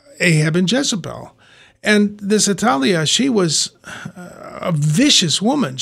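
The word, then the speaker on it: Athaliah: Well, there was a woman named, I would pronounce the name Atalia, who was the daughter of, the voice might just said like the Italian airline.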